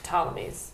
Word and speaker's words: Ptolemies: Ptolemies with a P in front, with a silent P. Ptolemies.